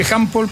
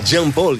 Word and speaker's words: Jean Paul: Porque se escribe Jean Paul, pero es Jean Paul. – Jean Paul, se pronuncia Jean Paul.